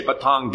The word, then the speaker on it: Patong: It's spelled P-A-T-O-N-G, but they say Batong Beach.